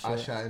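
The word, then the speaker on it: Asa: I don't know if it's pronounced Asha. – Asha, I know.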